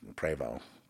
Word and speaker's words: Prevost: You just look for a Prevost on the back, Prevost, that's how it's pronounced.